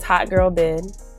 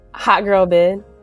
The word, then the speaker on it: Hot Girl Byd: My name is Hot Girl Bid. – Is what? – Hot Girl Bid.